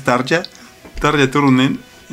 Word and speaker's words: Tarja Turunen: Tarja, la otra vez yo pronunciaba Tarja, pero pues suena como Tarja acá, como que fregadero, ¿no? Y es Tarja Turnen.